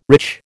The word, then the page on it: rich: American English